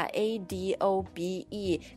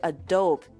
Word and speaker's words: Adobe: Adobe, 啊，这个因为你看它的拼法 A D O B E，可能很容易念成 Adobe。 – Adobe, yeah. – 是吧？确实，按照英语发音的规则规律来讲，应该是 Adobe。但其实这个软件公司，它这个品牌名叫 Adobe。